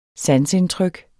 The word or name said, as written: sanseindtryk